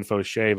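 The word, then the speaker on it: Faucher: The good Dr. Foucher was added in many leagues, but the jokes, it's fun, it's a joke, but I didn't notice it's pronounced Fauché. I found out over the weekend it's Calvin Fauché, but I like to laugh, people, and that's what's good in life.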